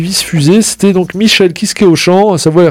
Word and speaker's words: Michael Kiske: Alors, là, c'est la vraie prononciation. Hein. Il y en a qui disent Michael Kiske. Non, c'est Michael Kiske.